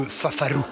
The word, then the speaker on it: fafaru: Et si on veut vraiment voyager, il faut bien prononcer en roulant bien les R, le fafaru.